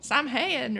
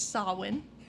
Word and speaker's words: Samhain: So, Halloween has its roots in a Celtic festival called Samhain, which is not spelled at all how you would think it would be pronounced. Which I thought was going to be like Samhain or something. But no, it's Samhain. I had to look it up.